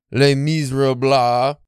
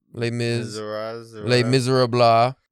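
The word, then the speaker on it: Les Misérables: We're going to read Les Miserables. – Is that Les Miserables. I looked up the pronunciation. No. It ends in blah. – That, I don't like that one. – Les Miserables.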